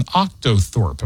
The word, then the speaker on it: octothorpe: It's called an octothorpe. Am I saying it right? – Yeah.